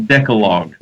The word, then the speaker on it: Decalogue: But you got everything right up to the Decalogue, and then you Decalogue. – That's okay. – Right. – That's okay. But it is pronounced the Decalogue.